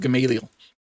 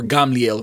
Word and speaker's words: Gamliel: He was highly trained, sat at the feet of Gamaliel, or Gamliel, if you want to say the Hebrew pronunciation.